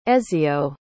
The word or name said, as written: ezio